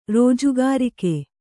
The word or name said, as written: ರೋಜುಗಾರಿಕೆ